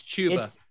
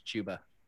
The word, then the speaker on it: Chuba: Is it Chuba or is it Chuba? – It's Chuba. – It's Chuba. – I got corrected on this way back during best ball season. The pronunciation bros came after me. It is Chuba.